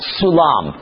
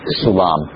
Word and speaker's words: Sulam: Sulam, and that is pronounced with a S as opposed to a K sound there. Sulam.